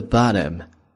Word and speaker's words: bottom: Bottom 本来这么去读，那么美式发音中间的 t 读的这个地方就是 at the bottom， at the bottom。